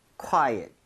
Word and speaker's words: quiet: U의 강세를 좀 찍어서 읽을 때 거기 강세 주면요, 엇보다도 at에 가깝게 오히려 발음이 될 겁니다. So 약간 quiet, quiet 이런 느낌으로.